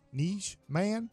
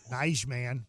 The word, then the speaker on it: Nijman: Starts with a Y. Niche man. N-I-J. Nijman. – Just let it happen. Yeah. – How would you pronounce N-I-J? Go. Nijman.